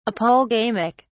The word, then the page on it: apolegamic: British English